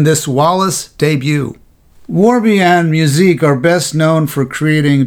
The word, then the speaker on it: Musique: Musique is spelled like the word muse and then I-Q-U-E.